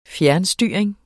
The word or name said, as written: fjernstyring